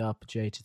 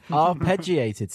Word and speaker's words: arpeggiated: Well, I said it wrong, didn't I? – Yeah, you did. – Arpeggiated synth, or as some people would say, Yanni. – Arpeggiated. – Thank you.